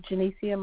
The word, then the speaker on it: Jenecia: Is Janicia my first name? J-E-N-E-C-I-A.